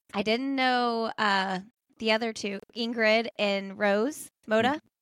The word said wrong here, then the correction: Rosa: Am I saying her name right? – Rosa.